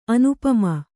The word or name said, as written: ಅನುಪಮ